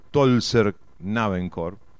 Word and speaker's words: Tölzer Knabenchor: El coro de niños de Tölz, como se dice que de esa manera, o sea, se escribe Tölz, pero con diéresis, la O, que se dice Tölzer Knabenchor.